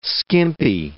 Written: skimpy

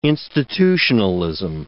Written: institutionalism

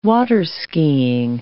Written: waterskiing

